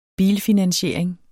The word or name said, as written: bilfinansiering